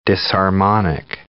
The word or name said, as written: disharmonic